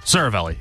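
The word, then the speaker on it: Seravalli: I believe I'm saying this right, but correct me if I'm wrong. Again, you are the hockey expert here on the show, you and Alec. Frank Saravalli? – Saravalli, yeah.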